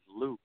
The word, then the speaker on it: Luc: The phone and handle you because you are pronouncing my name wrong, sweetheart. It is Luke.